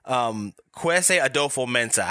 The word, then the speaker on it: Kwesi Adofo-Mensah: Kwesi Adofo-Mensah.